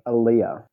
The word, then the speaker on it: Aaliyah: I believe it's pronounced Alia.